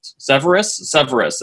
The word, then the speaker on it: Severus: How did you say his name? – Severus. It's Severus.